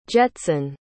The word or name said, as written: Jetson